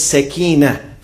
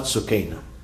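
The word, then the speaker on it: سكينة: In Arabic, the right pronunciation is sakina, from tranquility, not sukaina. Many people say sukaina, it's not right.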